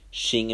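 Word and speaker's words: Qin: Issei is the emperor of the Shin Empire, if I remember. I think that's how they say it. It's with a Q, though. But I think it's pronounced Shin.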